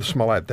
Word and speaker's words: Smollett: No, actually, I would have said Smollett myself. – Okay. – But with respect to my colleague in the School of Business, I'm with the Helms School of Government. – Oh, I'm sorry, School of Government. We'll go with Smollett then. I'll go back to my original pronunciation.